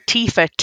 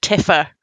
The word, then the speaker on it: Tifa: It's like people calling Tifa Tiffa.